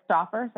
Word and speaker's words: Stouffer: Well, I will respond to anything, but it's Stouffer, so it like rhymes with offer, you know.